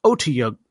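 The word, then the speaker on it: otyugh: Standing at the bottom of the cistern, Tiberius and Kashal and Keyleth stare down a giant Otiug. How do you say it again? – You said it right.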